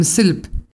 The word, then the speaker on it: CEFIMSLP: Les juro que no lo dije mal. Se dice tal cual así, Silp, que es la Coordinación Estatal para el Fortalecimiento Institucional de los Municipios de San Luis Potosí.